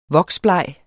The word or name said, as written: voksbleg